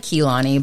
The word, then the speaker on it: Kehlani: You know, Kalani may very well... Did I say that right? – It's like Kehlani.